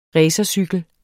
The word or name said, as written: racercykel